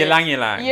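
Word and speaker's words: ylang-ylang: Look, that's your soothing lip balm because this is mine, which is the Lang Lang. How do you say? – Ylang ylang. Ylang ylang.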